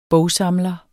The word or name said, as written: bogsamler